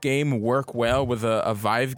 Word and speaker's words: Vive: When Oculus Rift, yeah, the Hive, yeah, is that what it's called? – No, Vive. – The Vive.